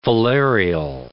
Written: filarial

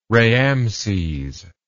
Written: raamses

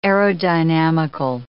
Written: aerodynamical

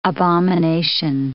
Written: abomination

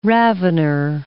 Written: ravener

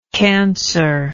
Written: cancered

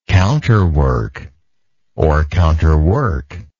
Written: counterwork

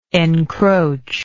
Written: encroachingly

